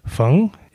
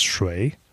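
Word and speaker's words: feng (風水): Feng in Mandarin, feng, it means the wind. Shui was actually pronounced shui, that means water.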